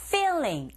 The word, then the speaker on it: feeling: feeling 앞글자 소리 제대로 내시면 되죠. 필링 아니에요, 여러분. 필링하게 되면 의미가 완전히 변합니다.